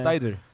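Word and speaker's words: Tidal: 이 타이 들이라고 읽어요. 타이 다리를 읽어요. 하여 타이 달. 타이들.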